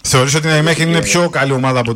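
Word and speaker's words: Nijmegen: Ναι, βέβαια εγώ θα ήθελα να πω ότι θεωρώ ότι είναι καλή ομάδα η ΑΕΜΕΧΕΝ. Ή Ναϊμέχεν, όπως ο καθένας θέλει να την προφέρει. – Ναι, μου είπαν ότι είναι Ολλανδικό Ναϊμέχεν.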